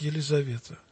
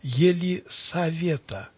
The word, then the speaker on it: Елисавета: Нельзя писать Елизавета. надо писать Елисавета, не Зе, а Се, потому что это имя произносится в церкви не через букву Зе, а через Се.